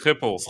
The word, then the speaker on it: Schiphol: Schiphol, is that how you pronounce it? – Schiphol. That's it.